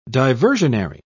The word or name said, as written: diversionary